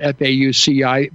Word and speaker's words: Fauci: It's spelled F A U C I, but it's pronounced Fauci.